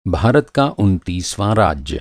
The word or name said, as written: उनतीस